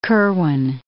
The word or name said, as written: Kirwan